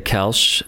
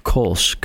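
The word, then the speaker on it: Koelsch: Yes, you could say Kelsch. That's how my family pronounces it. And I'll also say Kolsch, kind of like the beer Kolsch. And I'll spell it too. – Please. – It's K-O-E-L-S-C-H.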